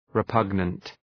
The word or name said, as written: repugnant